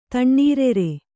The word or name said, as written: ತಣ್ಣೀರೆರೆ